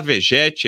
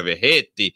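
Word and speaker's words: Vegetti: eu vou falar Vegete aqui e aí depois a gente vai conversar sobre a pronúncia correta, que na Argentina seria Verrete mas ah, vamos de vegete aqui, a portuguesar a coisa.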